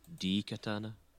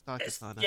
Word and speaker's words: Daikatana: I believe it's pronounced D-Katana. – No, it's Daikatana. – It's Daikatana.